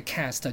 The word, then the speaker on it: caste: 哦，它应该是念 caste，cast。但是它的音标写 cast，就是种姓制度。所以我们有 hierarchy，还有 caste 的种姓制度。